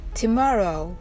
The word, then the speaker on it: tomorrow: tomorrow の最後は唇を突き出してうの発音をしてください。